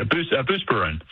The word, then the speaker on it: Buspirone: I mean, she was on a boatload of Buspirone. Am I saying that correctly? Probably not. – Yeah, Buspirone.